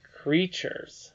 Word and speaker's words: creatures: More creatures. That's a funny way to spell a word, isn't it? – I don't see the CH. – Yeah, the T is pronounced ch here.